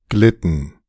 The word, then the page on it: glitten: [ˈɡlɪtn̩]; -ɪtn̩